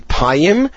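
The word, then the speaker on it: payim (פים): And suddenly we realized what a pim was. A pim is a weight. Actually, the proper pronunciation of it is probably payim,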